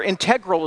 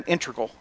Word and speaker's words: integral: or integral as I hear it pronounced these days. I always grew up hearing integral, but so you can choose your own pronunciation.